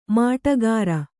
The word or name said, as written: ಮಾಟಗಾರ